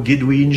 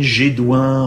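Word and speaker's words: Gédoyn: Alors on prononce différemment, Guédouin, Gédouin, enfin là vous pouvez, suivant les endroits où vous allez, avoir différentes prononciations.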